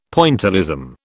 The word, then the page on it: Pointillism: British English